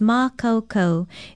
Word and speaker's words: makaukau: Aloha, I'm Lilani Poliahu of Hawaii Public Radio, and our Hawaiian word for today is makaukau. It is spelled M-A-K-A-U-K-A-U with a stress over the first vowel, and it means able, competent, prepared.